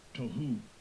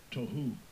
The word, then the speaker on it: tohu: Strong's H, 8414, Tohu, Tohu.